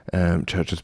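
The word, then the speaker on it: CHVRCHES: Churches spelled with a V, of course.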